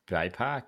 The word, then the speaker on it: BIPOC: Is that how it's pronounced, BIPOC? – Uh-huh.